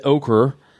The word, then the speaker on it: ochre: When archaeologists opened the graves of Grassy Island, there's another word I can't pronounce we were talking about earlier, O-C-H-R-E. Red okra, but not the okra. – Boy, I am dumb. – So much ochre.